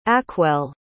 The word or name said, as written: acwel